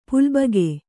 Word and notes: ಪುಲ್ಬಗೆ: pulbage

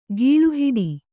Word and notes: ಗಾಳಿಹಿಡಿ: gāḷi hiḍi

